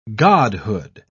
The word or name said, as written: godhood